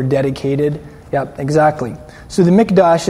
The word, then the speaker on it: mikdash: It's a mikdash. Everybody say mikdash. And you spell that M-I-K. In English you spell it M-I-K. D-A-S-H. Mikdash.